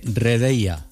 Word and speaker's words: Redeia: Buenos días, Patricia es delegada regional del Centro Este de Redeia.